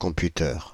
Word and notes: computeurs: /kɔ̃.py.tœʁ/; France (Île-de-France)

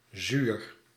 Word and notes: laborieux: France; [la.bɔ.ʁjø]

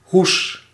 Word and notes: sleeve: IPA: /sliːv/

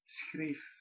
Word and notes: schreef: /sxreːf/

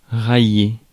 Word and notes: railler: France; IPA: [ʁɑ.je]

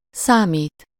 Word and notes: compter: France; [kɔ̃.te]

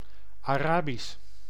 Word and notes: Arabisch: [ɑ.ˈrɑ.bis]; [ɑ.ˈʀɑ.bis]; /ɑˈraːbis/

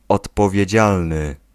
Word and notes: responsable: France; [ʁɛs.pɔ̃.sabl]

